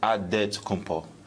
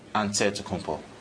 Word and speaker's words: Antetokounmpo: Greek way, you gotta say with a D, so it's Antetokounmpo. But in the Greek way, you say with the NT, like Antetokounmpo. So I changed myself.